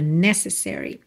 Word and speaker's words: necessary: A palavra necessary tem a sílaba tônica na primeira sílaba, necessary. Só que como ela é uma palavra longa, a gente acaba tendo um outro apoio extra no se também, né?